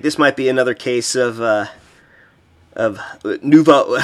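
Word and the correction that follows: Nova União: Nuva oh, Nova, Nova Uniao.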